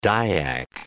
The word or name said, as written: Dayak